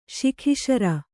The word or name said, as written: ಸುಕೇಸರ